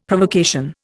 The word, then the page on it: Provocation: Indian